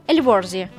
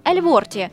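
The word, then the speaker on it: Elworthy: Якщо читати англійською, то це буде звучати приблизно так. Ельворзі. Вибачте за мою англійську. Поєднання англійських букв у кінці T та H читається як the. Пам'ятаєте, як наполегливо нас вчили вимовляти цей звук у школі? Але для єлисаветградців зручніше було говорити Ельворті. До того ж, сам Роберт також вживав цей варіант свого прізвища, коли переїхав у Єлисаветград.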